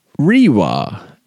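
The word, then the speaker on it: Reiwa: And they uh, replied here saying, actually, we pronounce it Rewa.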